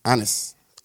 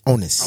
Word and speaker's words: onus: Yeah, how do you pronounce it? – Honest? – Can you spell it? – Onus. – O-N-U-S. – You got to own this shit. Onus. – O-N-U-S.